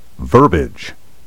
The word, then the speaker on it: verbiage: But back to the word verbiage, which is often mispronounced, verbiage, as if it had only two syllables. Carriage and marriage have two syllables, but verbiage and foliage have three.